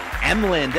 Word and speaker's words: Emlen: I'm your host, and I'm here with a very, very special guest, Hazel Emlen.